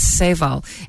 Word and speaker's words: Seyval: I keep forgetting it's Barrel Oak Wineries, B-O-W. They're very dog friendly, but it is in Virginia. And this is a Sauval Blanc. – And he's not just, he didn't mess up the word. It's not Sauvignon Blanc, it's Sauval. And that's spelled S-E-Y-V-A-L Blanc.